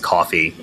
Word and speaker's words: Ko-fi: Is the Ko-fi, K-O-F-I, although I do think it's pronounced coffee as well, but I just call it Ko-fi.